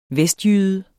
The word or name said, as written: vestjyde